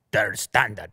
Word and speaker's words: Der Standard: Der Standard. That's how they say it.